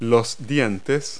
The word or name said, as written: teeth